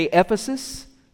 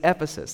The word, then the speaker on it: Ephesus: Say Ephesus. It sounds like emphasis mispronounced, but it's Ephesus.